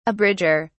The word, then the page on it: abridger: British English